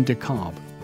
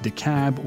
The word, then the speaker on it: DeKalb: The name of the county is usually pronounced DeKalb or DeKalb, but it's the thought that counts.